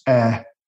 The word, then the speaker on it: heir: And I said, well, because he didn't have any hair, because I didn't know that you pronounced air, H-E-I-R. – Oh, brilliant. – Soft H.